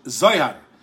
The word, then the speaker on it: Zohar: As we say in the Ashkenazic pronunciation, Zohar.